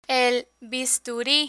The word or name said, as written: el bisturi